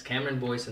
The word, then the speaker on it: Cameron Boyce: Cameron Boyce, is that how you say it? – Yes, Cameron Boyce unfortunately has passed.